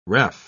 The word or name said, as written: ref